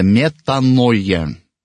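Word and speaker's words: метаноя (μετάνοια): По-гречески покаяние произносится метаноя. И буквально переводится как перемена ума.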